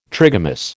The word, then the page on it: trigamous: American English